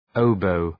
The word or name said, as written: oboe